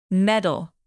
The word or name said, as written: medal